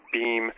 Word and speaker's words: Bhima: It's spelled B-H-I-M-A. I think they usually drop the A off on his name, Bhima or Beam.